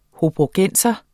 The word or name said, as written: hobrogenser